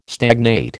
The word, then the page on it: stagnate: American English